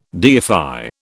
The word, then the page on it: deify: British English